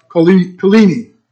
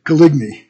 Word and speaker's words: Coligny: There was once a sincere Christian admiral in France whose name was Gaspard Coligny. And if you're from Tennessee, that is Gaspard Coligny. But that's not the way they pronounce it in France, of course.